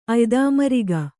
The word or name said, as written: ಅಯ್ದಾಮರಿಗ